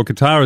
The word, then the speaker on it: Qatar: Well, they're saying that in Qatar or Qatar, as some pronounce it,